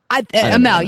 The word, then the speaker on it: Amell: How do you say his name? Amell? – Amell, yes. – Okay, I have no idea. I don't watch that show. I'm so, so sorry. Stephen Amell and Stephen Amell and Neville, and then it'll be Stardust and Bad News Barrett, Wade Barrett, whatever they're calling him now.